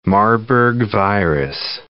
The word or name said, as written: Marburg virus